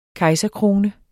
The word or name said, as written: kejserkrone